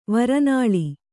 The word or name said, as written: ವರನಾಳಿ